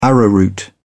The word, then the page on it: arrowroot: British English